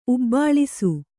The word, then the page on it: ಉಬ್ಬಳಿಸು: ubbaḷisu